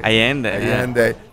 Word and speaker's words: Allende: Allende.